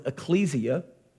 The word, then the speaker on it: ekklesia: Well, church, the word, comes from the Greek word ekklesia, or some pronounce it ecclesia.